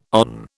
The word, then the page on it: on: British English